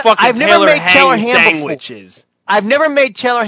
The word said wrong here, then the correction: Taylor Ham: Why can you say it right there and not when you say the whole thing? – I don't know what the procedure is. – Say it after me. Taylor Ham.